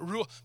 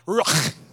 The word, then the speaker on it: ruach: But before you say it, you got to say it with like like you got like you got popcorn hung in your throat. Okay, ruach, ruach. Right? That's how you say it okay ruach that's that's the that's the, the the original language in hebrew it